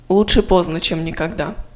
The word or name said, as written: лучше поздно чем никогда